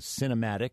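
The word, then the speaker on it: Sinematic: which was Cinematic, emphasis on the sin, S-I-N, not C-I-N.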